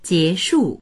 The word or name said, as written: jie2shu4